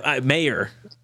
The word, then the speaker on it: Mayer: Mager. I'm a Notre Dame fan. So I keep hearing you butcher the last name. – Mayer.